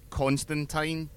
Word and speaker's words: Constantine: When in 2013-ish, when they made the Constantine TV show, which is how you pronounce his name, it's how they say it in the comics, he makes that point several times, and I once got a reply from Neil Gaiman on Twitter confirming it.